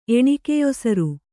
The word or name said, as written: ಎಣಿಕೆಯೊಸರು